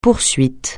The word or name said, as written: poursuite